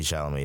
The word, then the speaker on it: Chalamet: I'll watch anything with Timothy Clement. – But Timothy Chalamet is amazing. – Is that how you say it? – Yeah, Timothy Chalamet.